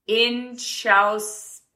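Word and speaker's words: Inchauspé: Her name is Jessie Inchauspe with an accent on the E.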